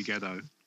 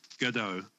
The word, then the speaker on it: Gadot: I'm just looking in, Mark, it's phonetically, it does appear, it does appear to be Gedo. – Silent T. – Gedo.